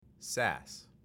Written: SaaS

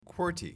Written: QWERTY

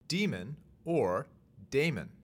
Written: daemon

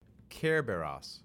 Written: Kerberos

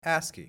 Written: ASCII

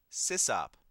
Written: sysop

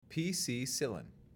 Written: PC-cillin

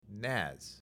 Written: NAS

